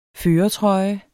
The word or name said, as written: førertrøje